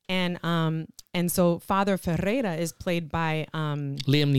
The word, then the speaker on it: Ferreira: that go on this mission to find um, Father Ferreira. Mm-hmm. Ferreira, excuse me. Yeah. That's how you say it.